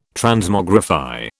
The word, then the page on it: Transmogrify: British English